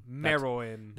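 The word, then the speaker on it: Meruem: Merowim, Merim. Merowim, Merowim. That's, that's how that's how that's how they say it.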